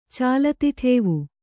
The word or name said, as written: ಚಾಲತಿಠೇವು